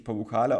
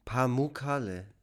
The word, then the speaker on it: Pamukkale: Also ich, ich habe gehört, dass die ähm, Betonung auf der zweiten Silbe liegt eigentlich. – Pamukkale, Pamukkale.